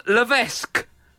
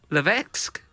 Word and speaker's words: Levesque: Paul Levesque. Levesque. – Levesque. – Oh, it's spelled L-E-V-E-S-Q-U-E.